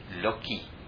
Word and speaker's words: Lakshmi: So, Lakshmi, that name, there are different local dialects of pronunciation for Lakshmi. And I've told the story before, and in parts of India she is called Loki. Loki.